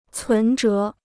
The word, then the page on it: 存折: cún zhé